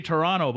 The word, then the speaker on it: Toronto: You say Toronto, buddy. You don't pronounce the second T in Toronto. Every good-blooded Canadian man, woman, and child knows that.